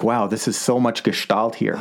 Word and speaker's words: Gestalt: Gestalt, yeah. Gestalt. It's a German word. It's a German word for structure, okay.